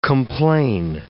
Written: complain

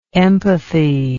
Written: empathist

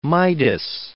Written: Midas

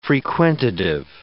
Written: frequentative

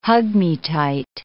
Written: hug-me-tight

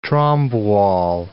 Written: Trombe wall

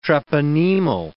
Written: treponemal

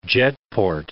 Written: jetport

